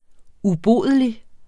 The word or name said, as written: ubodelig